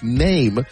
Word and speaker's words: Nehm: We'll spend some time with our new best friend from The Athletic, Eric Name, a name to know, spelled N-E-H-M, but pronounced name.